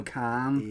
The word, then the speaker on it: Cannes: I feel like Cannes. I think it's pronounced, isn't it? C-A-N-N. – No, Cannes. – Yes, Cannes. – Or Cannes. – Cannes. Yeah, right, Cannes.